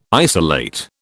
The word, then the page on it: isolate: British English